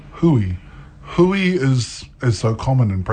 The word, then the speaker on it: hui: I've heard Huey. – Okay. – Yeah, and I didn't like that.